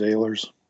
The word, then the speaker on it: Ehlers: That's how everybody says it. That's actually how I say it. But the correct German pronunciation is Ehlers.